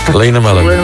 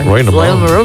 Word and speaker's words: Lynn Marlin: Zawsze mam wątpliwość, jak to się wymawia. – Lynn Marlin. – Lynn Marlin.